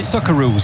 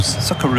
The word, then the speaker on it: Socceroos: Très belle prononciation, hein. – Oui. – Socceroos. – Socceroos. – Donc la contraction entre le soccer et les kangourous, évidemment.